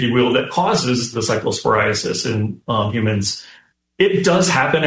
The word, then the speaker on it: cyclosporiasis: I'm going to probably say it wrong, the cyclosporiasis outbreak. – The cyclosporiasis. – Yep. Okay, very thank close.